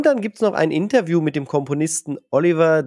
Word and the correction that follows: Olivier: Derivier, habe ich das richtig gesagt, von Dying Light the Beast? Olivier? – Ja, genau.